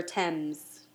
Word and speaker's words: Thames: Thames.